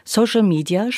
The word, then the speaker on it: Social Media: Social Media spricht man so aus. Social Media.